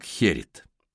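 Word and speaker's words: Gerrit: Его имя произносится как Херит.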